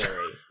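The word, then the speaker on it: Ga'yriii: It's G-A apostrophe Y-R-I-I-I. – Mm-hmm. Something like that. – Mm-hmm. – Yeah, but it's pronounced Gary.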